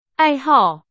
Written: 爱好

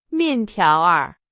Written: 面条儿

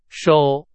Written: 手

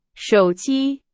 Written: 手机

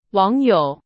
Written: 网友

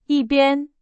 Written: 一边

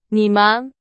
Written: 你们

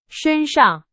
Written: 身上

